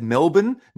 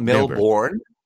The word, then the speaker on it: Melbourne: You said Melbourne, not Melbourne. – Right. – Americans would be very common to say Melbourne.